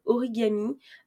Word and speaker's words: Origame: Euh, c'est une startup qui s'appelle Origami. Alors ça s'écrit comme Origame, euh, O-R-I-G-A-M-E.